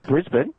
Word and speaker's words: Brisbane: Now that I know how to pronounce it correctly, Steve, I'll call it Brisbane, just like he wants. Right? Did I say it right? – Brisbane? – yeah, that's what I...